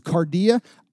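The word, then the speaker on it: kardia: It's pronounced cardia.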